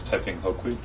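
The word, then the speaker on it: Taiping Houkui: The proper pronunciation is Taiping Hokui.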